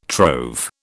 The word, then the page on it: trove: British English